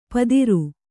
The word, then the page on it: ಪದಿರು: Kannada